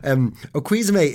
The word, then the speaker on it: Okuizome: Tell me then about Okuizume. No, I've just said that wrong. Did I? – No, you said it right. I think you said it right that time.